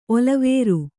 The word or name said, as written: ಒಲವೇರು